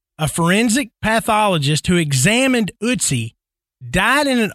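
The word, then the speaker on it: Ötzi: The curse of Utsi.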